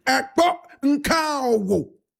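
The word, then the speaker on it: Ekpo Nka-owo: Now, this tradition is tied to a powerful, unforgiving marital spirit called Ekpo Nkawo. Can I repeat it? Ekpo Nkawo.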